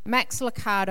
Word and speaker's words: Lucado: Max Lucado, I think that's how you say it. Yep.